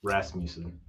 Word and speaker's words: Rasmussen: Well, we have for Atlanta Doug Edwards and Blair Rasmussen. I think I said that right. Rasmussen. Yeah. There we go.